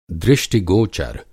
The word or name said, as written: दृष्टि-गोचर